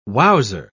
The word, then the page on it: wowser: British English